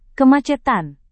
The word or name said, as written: kemacetan